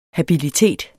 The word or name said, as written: habilitet